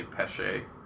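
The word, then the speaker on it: Festina Pêche: And actually, this Festina Peshe, is that how you say it? – I say Pesh. Some people say Peshe. I don't know.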